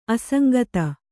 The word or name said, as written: ಅಸಂಗತಿ